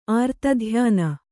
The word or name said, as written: ಆರ್ತಧ್ಯಾನ